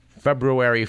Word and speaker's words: February: Our date in question is February 4th. We like to remind listeners every year that there are two R's in February, and they're both pronounced.